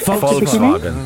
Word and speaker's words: Volkswagen: Not even Volkswagen or... – Yeah, how do I pronounce Volkswagen? Is it Volkswagen? – Volkswagen. – Volkswagen. – It is. – More like an F-ish. – Volkswagen.